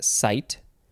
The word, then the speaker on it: cyte: It is C-Y-T-E, so you probably pronounce that cyte.